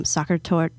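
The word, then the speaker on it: Sachertorte: I did the soccer tour. Yet. I don't know if I said that right. How do I say it? – You said it right.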